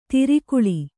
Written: ಧಾರೆಗಾಳೆ

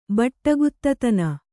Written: ಬಟ್ಟಗುತ್ತತನ

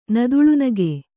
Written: ನದುಳುನಗೆ

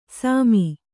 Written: ಸಾಮಿ

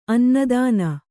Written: ಅನ್ನದಾನ

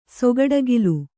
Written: ಸೊಗಡಗಿಲು

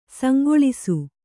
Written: ಸಂಕಲಿಸು

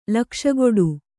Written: ಲಕ್ಷಕೊಡು

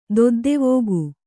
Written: ದೊದ್ದೆವೋಗು

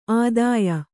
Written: ಆದೇಯ

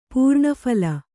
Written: ಪೂರ್ಣಫಲ

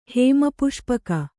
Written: ಹೇಮಪುಷ್ಪಕ

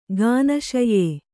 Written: ಗಾನಶಯ್ಯೆ

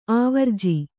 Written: ಆವರ್ಜಾ

